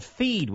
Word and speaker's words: Pheed: Then there's one called Feed, which you may not have heard of. It's spelled P-H-E-E-D.